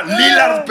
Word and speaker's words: Lillard: Lillard, pendejo, ponle acento en la I, chingada madre.